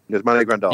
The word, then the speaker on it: Grandal: They signed Grande, or I can't even say his name right. – Yasmani Grandal.